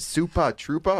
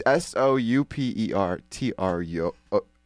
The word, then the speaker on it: Super Trooper: Is it Supa Troopa or Super Trooper? I don't know, Paige. What do you think? – How's it spelled? – It's Super Trooper. – I guess Super Trooper.